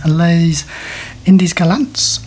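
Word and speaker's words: Les Indes galantes: And I believe the French pronunciation of that work is Les Indies Galantes, which is...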